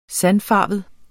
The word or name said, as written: sandfarvet